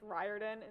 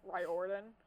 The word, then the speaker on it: Riordan: Still get his name wrong after finding out that it's Riordan instead of Riordan.